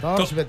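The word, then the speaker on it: Thorsby: Si lo he pronunciado bien. – Tors, sí, absolutamente. – Torsbet.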